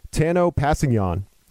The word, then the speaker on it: Kpassgnon: But Chiefs defensive end Tanoh Kpassgnon, former second-round pick, or he was a second-round pick in the 2017 draft.